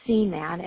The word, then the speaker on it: Seaman: Or my email address is my first initial A, like Amber, my last name, which is Seaman, and it's spelled S-E-A, like the ocean, M-A-N, like man, at S, like servicing, C, like corporation, S, like servicing.